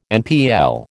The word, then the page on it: NPL: American English